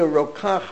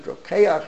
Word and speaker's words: Rokach: Whenever you meet a Rokach, or they pronounce it Rokeach, Rokach, they're all interrelated.